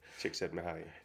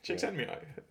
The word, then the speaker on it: Csikszentmihalyi: It's quite easy once you get the trick, but... – Csikszentmihalyi. – Csikszentmihalyi, that's it. But